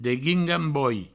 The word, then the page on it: deguingamboi: Provençal